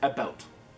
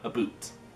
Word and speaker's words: about: And I pronounce it about, not a boot.